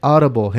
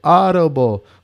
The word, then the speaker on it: Audible: Audible se escribe o se dice en español, un disparate. Se dice Audible, gente. Audible. Lo ahora con el inglés de este.